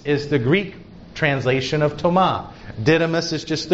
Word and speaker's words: Toma: The way you would pronounce it in Aramaic is Toma, T-O-M-A, Toma.